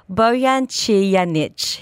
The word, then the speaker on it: Bojan Cijanić: So I'd like to welcome on the show, and I'm really going to try and pronounce this well, Bojan Cijanić. He is going to be the next executive chef of Folly from the end of this month. Did I pronounce that right, Chef? – Yes, you did, actually.